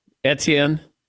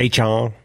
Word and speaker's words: Etienne: Also, Travis Etienne is running his 40 today, although I might be pronouncing it wrong. – Etienne, it's okay. – H-on.